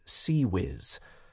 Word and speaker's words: CIWS: He was unhappy that they were relying on the Close-In Weapons System, CIWS, commonly pronounced CWIS.